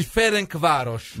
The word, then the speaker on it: Ferencváros: Ma le a seguire il Ferencváros. – Bravo, bravo.